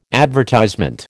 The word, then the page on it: advertisement: American English